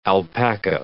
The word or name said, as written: alpaca